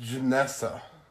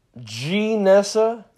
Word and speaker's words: Gnesa: It's actually pronounced Janessa. Janessa. Yes.